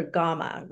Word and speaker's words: Gama: I called her Gama, rhymes with mama.